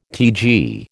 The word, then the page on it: TG: American English